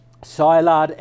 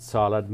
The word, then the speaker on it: Szilard: Szilard, S-Z-I-L-I-R-D. So Szilard, I pronounce it Szilard, maybe that's slightly incorrect, but Leo Szilard.